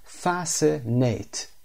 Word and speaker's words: fascinate: Fascinate, تلفظ fascinate, استرسم روی fast, fascinate به معنی to attract or interest somebody very much, یعنی جذب کردن یا باعث علاقه‌مندی شدن کسی نسبت به چیزی.